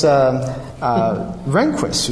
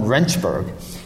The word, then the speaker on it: Rehnquist: It was Rehnquist, who was actually the future Supreme Court justice. But Nixon mispronounced his name, called him Rehnchberg.